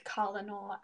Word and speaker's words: colonel: It's colonel, actually. – Yeah. – It'll always be colonel.